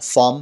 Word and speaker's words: thumb: مورد اول فام هست. بی در انتهای کلمه تلفظ نمیشه. فام. فام.